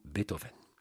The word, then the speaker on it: Beethoven: L'émission de ce soir va être consacrée à l'un des plus grands génies de toute l'histoire de la musique, Ludwig van Beethoven, avec la prononciation allemande, cependant par commodité ce soir, nous dirons Beethoven.